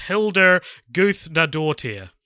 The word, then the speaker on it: Hildur Guðnadóttir: Her name is pronounced Hildur Guðnadóttir. There you go.